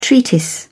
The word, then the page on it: treatise: British English